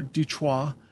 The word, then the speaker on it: Detroit: This is during a lot of the, you know, a lot of race relations are still very heated, especially in Detroit or Detroit, which is the way it should be pronounced.